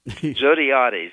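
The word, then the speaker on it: Zodhiates: Now, how do you say his last name again? I can never get this right. – Zogbiates. – Zogbiates.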